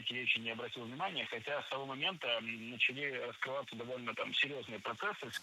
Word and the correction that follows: начали: Начали неправильно. Должно быть «начали». Ударение в этом слове падает на первый слог.